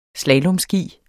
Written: slalomski

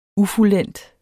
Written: ufuldendt

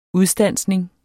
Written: udstansning

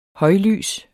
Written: højlys

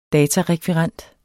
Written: datarekvirent

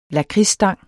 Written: lakridsstang

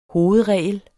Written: hovedregel

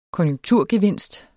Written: KONJUNKTURGEVINST

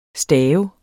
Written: staver

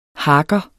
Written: harker

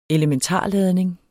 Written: elementarladning

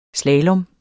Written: slalom

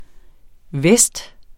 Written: vest

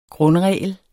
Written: grundregel

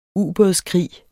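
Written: UBÅDSKRIG

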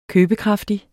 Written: købekraftig

